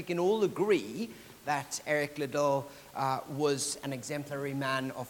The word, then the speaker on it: Liddell: But this verse reminds me of a man named Eric Liddell, or Eric Liddell, I think it's pronounced here, Eric Liddell.